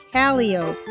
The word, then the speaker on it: calliope: Oh, not a so it's spelled the same, but it's pronounced calliope.